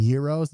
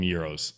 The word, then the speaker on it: gyros: gyros. Gyros or gyros? – I call them Euros. – Euros is the correct pronunciation of that term. The Americanized version is gyro.